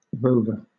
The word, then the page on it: rover: British English